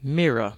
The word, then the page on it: mirror: British English